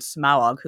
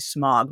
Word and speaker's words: Smaug: I always used to say Smog, but no, it's Smaug. Eh, we got to get that kind of second syllable in there somehow.